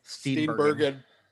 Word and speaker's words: Steenburgen: How do you say her last name? – Steenburgen. – It is Bergen. Yep. I always say Bergen, I think.